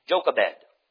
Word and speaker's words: Jochebed: Hebrews 11, verse number 24 to 26, talking about Moses and his mother, how would you pronounce this? J-O-C-H-E-B-E-D, Jochebed, right? That's what I would say, Jochebed, all right?